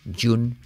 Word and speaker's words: June: No es una I, G-G-G, es una che, pero con voz. June, July, jacket.